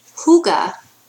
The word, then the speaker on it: hygge: Hygge is pronounced hygge.